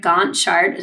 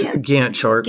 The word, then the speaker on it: Gantt: I built a JS Drive Gantt chart. Did I say that right? – It's Gantt chart. – Gantt chart. – Close enough.